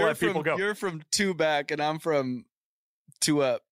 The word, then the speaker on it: Tubac: I think it's pronounced Tubac. That's how they say it down there, but it's T-U-B-A-C.